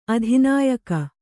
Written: ಅಧಿನಾಯಕ

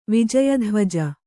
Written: ವಿಜಯಧ್ವಜ

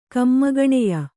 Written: ಕಮ್ಮಗಣೆಯ

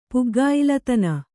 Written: ಪುಗ್ಗಾಯಿಲತನ

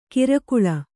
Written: ಕಿರಕುಳ

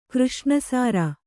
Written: ಕೃಷ್ಣಸಾರ

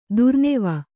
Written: ದುರ್ನೆವ

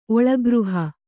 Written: ಒಳಗೃಹ

